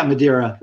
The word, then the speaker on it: Madeira: Now, talking about the history of the ukulele really quickly, you talk about, is it Madeira? Is that how you say it? – Yeah, Madeira, right.